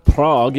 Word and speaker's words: Prague: Prague? – Prague is the next event.